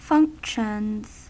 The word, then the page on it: functions: American English